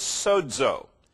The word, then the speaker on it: sozo: The Greek word behind it is sozo. S-O-Z-O, pronounced as if there's a D in there. Sozo.